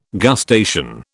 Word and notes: gustation: British English

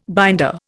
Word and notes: binder: Indian

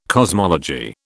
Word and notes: cosmology: British English

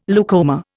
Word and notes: leucoma: Indian

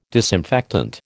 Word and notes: disinfectant: American English

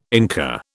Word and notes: Inca: British English

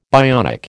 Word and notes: bionic: American English

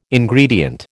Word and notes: Ingredient: American English

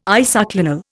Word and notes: isoclinal: Indian

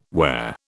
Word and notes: where: British English

